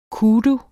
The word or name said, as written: kudu